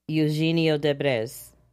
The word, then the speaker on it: Eugenio Derbez: Eugenio Derbez. See, Derbez. There is no way I would say that. – Eugenio Derbez.